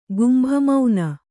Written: ಗುಂಭಮೌನ

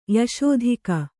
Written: ಯಶೋಧಿಕ

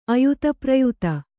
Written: ಆಯಾತಪ್ರಯಾತ

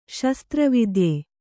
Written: ಶಸ್ತ್ರವೈದ್ಯ

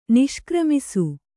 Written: ನಿಷ್ಕ್ರಮಿಸು

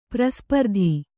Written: ಪ್ರಸ್ಪರ್ಧಿ

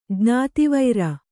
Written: ಜ್ಞಾತಿವೈರ

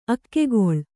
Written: ಅಗ್ಗಳೆ

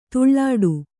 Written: ಧೂಳಾಟ

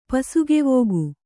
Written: ಪಸುಗೆವೋಗು

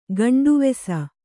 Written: ಗಂಡುವೆಸ

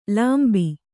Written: ಲಂಬ

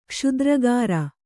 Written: ಕ್ಷುದ್ರಗಾರ

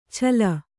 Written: ಛಲ

